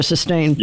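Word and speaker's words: Systane: I think it's pronounced Sustain. – Yeah, right. That's over-the-counter stuff. Yeah, Sustain.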